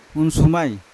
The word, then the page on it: unsumai: Yawa